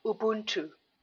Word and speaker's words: Ubuntu: Ubuntu.